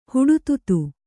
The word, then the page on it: ಹುಡುತುತು: huḍututu